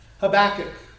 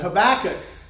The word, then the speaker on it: Habakkuk: I said Habakkuk because that was the way my Sunday school, she didn't use the, when I was a kid, she didn't use the last K. It was sort of like a silent letter.